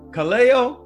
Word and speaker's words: Callao: C-A-L-L-A-O. Now people look at it and they say, Callao? I say, oh no, that's not how you pronounce it. It's Callao. It's like you go to New Orleans.